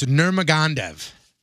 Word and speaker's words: Nurmagomedov: Khabib Nurmagomedov. – Not even close, but... – Okay, how do you say it? – I don't know. Let me see. – It's Nurmagomedov.